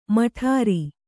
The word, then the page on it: ಮಠಾರಿ: maṭhāri; Kannada